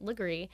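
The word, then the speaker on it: Legare: it's spelled Laguerre, but I'm told that to pronounce it Legree.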